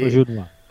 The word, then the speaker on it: Auberjonois: Non ho mai saputo pronunciare, quindi... – Auberjonois.